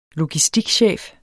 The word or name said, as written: logistikchef